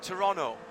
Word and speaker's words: Toronto: He's complaining that I'm pronouncing too many T's when I mention the major conurbation that is just to the south here. Sorry, I do know that it is Toronto.